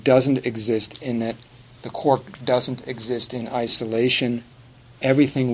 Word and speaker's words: quark: Most of us have taken at least a look at this thing called the quark, right? Q-U-A-R-K. Did I say it right? Quark. – Quark.